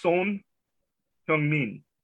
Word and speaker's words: Son Heung-min: So all I will say is it's not pronounced like the English word for male child. It is Son Heung-min.